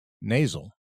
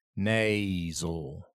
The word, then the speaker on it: nasal: And then, of course, there's the Z in nasal. Nasal. Nasal. Because there's a diphthong in the first syllable, nay. It's not na or ne. It's nay.